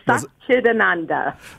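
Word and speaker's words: Satchidananda: She was among a select group of four individuals to receive the direct blessing of Dharma confirmation from Swami Sachidananda, again, wrong pronunciation, I'm sure, a rare and highly coveted honor. Welcome to the show. How did I do? Did I get it right? – You were close. Sachidananda.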